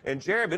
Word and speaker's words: Jarib: His name is Jarib Burnett. It's spelled J-A-R-I-B. It's a little hard for me to say, but Jarib Burnett.